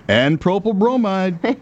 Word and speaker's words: N-propyl bromide: I hope I pronounce it correctly, N-propyl bromide. Dr. Mike? – Very good. That's perfect pronunciation. – N-propyl bromide.